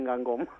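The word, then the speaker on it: Guingamp: Se escribe Gingam, unos dicen Gingam, otros allí dicen Gangom.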